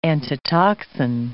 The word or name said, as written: antitoxin